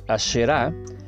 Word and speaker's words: Asherá: Acera ya después se escribe sin H, mientras que aquí Asherá se escribe A-S-H-E-R-A e con acento en la Asherah, y ese es un árbol que se plantaba con la finalidad de adoración pagana.